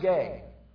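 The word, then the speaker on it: gay (γῆ): It's G-E, is how we would spell it. It's pronounced gay.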